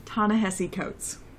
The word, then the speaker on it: Ta-Nehisi Coates: Ta-Nehisi Coates.